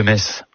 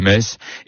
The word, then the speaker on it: Metz: Ayons une pensée ce matin pour les cousines de Metz en France, les mal prononcées, Gérard Mer et Chamonix.